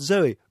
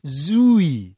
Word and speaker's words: Zooey: Sometimes people say, oh, it's pronounced Zoe. Wrong! It's pronounced Zooey. And even though it's spelt with only two O's, you actually have to extend it.